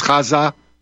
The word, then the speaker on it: Gaza: You have to use that first pronunciation, but you have to use the accent on the second syllable, Gaza.